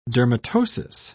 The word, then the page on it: dermatosis: British English